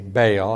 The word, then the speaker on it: Baal: So the Hebrew word is Baal. But in English, we usually say Baal.